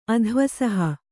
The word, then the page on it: ಅಧ್ವಸಹ: Kannada